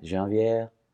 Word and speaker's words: janvier: Janvier, as they would say in French. – Is that how they say it? – Yeah. – Say it again? – Janvier. – That sounds so sweet when you say it. – Yeah, I mean, my wife might correct me a little bit there on the very ending of that. She's a French teacher and a French speaker. – Gotcha. – But I think I pronounced it correctly.